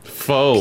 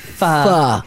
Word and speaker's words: pho: Pho. – P-H-pho. Huh? It is not pronounced pho. It is not pronounced po. It is pronounced pho.